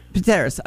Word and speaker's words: Pteris: The Pteris, is that how you say it? P-T-I-E-R-I-S? – Yes, Pteris. – Pteris.